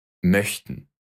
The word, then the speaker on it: Möchten: Möchten. Хотеть. Чтобы понять правильное произношение этой буквы, попробуйте произнести звук Э. Но сложите губы так, словно вы произносите О.